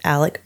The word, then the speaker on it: Alex: And his name is spelled A-L-E-X, like Alex, but they pronounce it, and he pronounces it himself as Alec or Alec. Because, you know, it's like Alec. It's just the way, the drawl that people have.